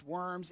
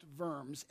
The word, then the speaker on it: Worms: And worms is not pronounced worms, it's pronounced worms, and it's a city near Heidelberg in Germany, okay?